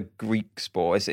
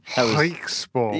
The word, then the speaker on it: Griekspoor: It's not a Griekspoor. It's a slightly different sound. – Griekspoor.